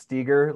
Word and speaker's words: Steger: And I hate to be that guy, but I'll just say my last name is actually pronounced Steger, like eager.